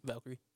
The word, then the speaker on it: Valkyrie: Is it Valkyrie? How you say it? – Valkyrie.